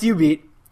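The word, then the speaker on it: jubeat: It's spelled J-U-B-E-A-T. I don't want to say... It's pronounced U-Beat.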